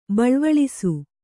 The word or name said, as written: ಬೆಳ್ವಳಿಸು